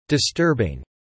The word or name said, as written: disturbing